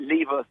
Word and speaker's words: Levi's: I know you could get murdered now for calling it Levi's, by the way. – Go on. I'd solve the argument. How do you pronounce it? – How you remember it is, leave us, as in leave us alone.